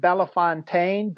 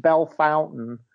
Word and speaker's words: Bellefontaine: Just got back from New Wrestling Era in, I pronounced it Bellefontaine, but I was told it's pronounced Bell Fountain, Ohio, which is down near Columbus.